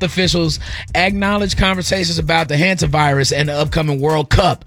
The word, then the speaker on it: hantavirus: It's hantavirus.